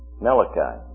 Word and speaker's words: Malachi: So it is sent then, it's signified by Malach-E, or as we pronounce it, Malachi. Malachi.